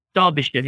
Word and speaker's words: Derbyshire: Now, it looks like Derbyshire to us Americans, but it's Derbyshire. That's how you say it? – Derbyshire, yeah, yeah.